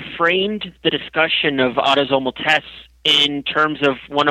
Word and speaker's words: autosomal: And I know in your new article, you're talking about, it's autosomal, am I saying that correctly? – That's right, autosomal.